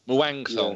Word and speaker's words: Muangthong: You had a quick loan at Sheffield Wednesday, and then you went to a team which I'm going to try and pronounce in the Thai Premier League, Muangthong. Yeah. Is that how you say it? – That's the only thing I've ever pronounced right in 7 years of podcasting.